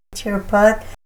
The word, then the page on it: chirpat: Ninilchik Russian